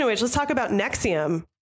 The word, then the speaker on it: NXIVM: Yes, this one's called like NXIVM. – Yeah, and it's just pronounced Nexium.